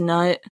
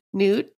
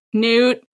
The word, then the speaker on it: Nut: It's spelled Nut. It doesn't seem like it should be pronounced Nut. – Newt? – Newt, maybe.